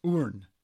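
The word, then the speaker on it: Hoorn: The first one is in Urn, Netherlands. – Oh. – Urn. It's spelled with an H, but you don't say the H.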